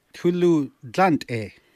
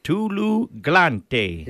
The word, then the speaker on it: Tulu glante: In Dene, how do you say, how's the road? – Tulu glante. – Tulu glante. – Yeah, that's perfect.